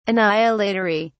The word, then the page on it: annihilatory: British English